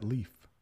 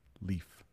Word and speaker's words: leaf: Yeah, leave is a little different from leaf. Leave. Hey, I need to leave in a few minutes. Oh, that's a pretty leaf on the tree. So just a little different pronunciation there.